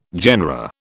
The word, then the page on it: genera: British English